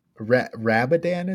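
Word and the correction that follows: Radobaan: is it? What's it? How do you say it? – Radoban. – Yeah. – You're correct.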